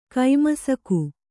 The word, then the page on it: ಕೈಮಸಕ: kai masaka